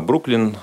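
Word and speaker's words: Brooklyn: место рождения это Бруклер, э, Бруклин, э, город, э, можно сказать, часть, район даже Нью-Йорка.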